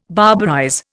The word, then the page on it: barbarize: Indian